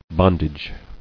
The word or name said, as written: Bondage